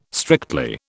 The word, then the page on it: strictly: British English